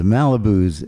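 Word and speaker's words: Malibooz: The Malibus is... – The Malibus, but it's B-O-O-Z. – B-O-O-Z.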